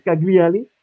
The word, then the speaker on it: Cagliari: Al Cagliari al Cagliari.